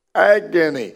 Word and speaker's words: agon: The Greek word, we would spell it A-G-O-N-E, pronounced agon, and from that word we get the word agony.